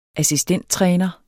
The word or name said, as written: assistenttræner